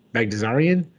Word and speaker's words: Baghdasaryan: Obviously, I know a lot more about England's game than I do I'm going to try not butchering his name, Bagdasarian.